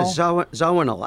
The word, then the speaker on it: Zawinul: How do you pronounce that? Zawinul? – Yeah, Zawinul.